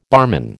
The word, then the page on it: barman: American English